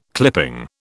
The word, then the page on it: clipping: British English